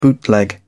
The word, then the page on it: bootleg: British English